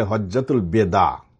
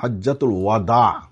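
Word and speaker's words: হজ্জাতুল ওয়াদা (حجة الوداع): দেখুন এই শব্দটি আমাদের দেশের মানুষেরা অধিকাংশই উচ্চারণ করে ভুল উচ্চারণ, বলে হজ্জাতুল বেদা, আসলে হজ্জাতুল বেদা না, হজ্জাতুল ওয়াদা। ওয়াওর উপর হলো জবর দিয়ে, ওয়াওর উপর ফাতা দিয়ে হজ্জাতুল ওয়াদা, অর্থাৎ হলো বিদায় হজ।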